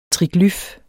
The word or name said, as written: triglyf